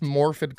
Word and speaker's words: Morfydd: I don't know how to say her name. I think it's Morfydd Clark, Morfydd.